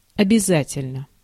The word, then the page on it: обязательно: /əbʲɪˈzatʲɪlʲnə/